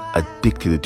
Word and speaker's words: addict: 那歌手利用歌词回忆了爱人之间相处的种种细节以后，最后他的结论就是 I've been addicted to you, addicted to somebody, 或者是 addicted to something, 表示沉迷于某事，完全对某事是已经上瘾了。 Addiction.名词，那它的名词还有 addict，发音变了啊，addict 就是瘾君子，drug addict 这个人是一个吸毒的人，drug addict。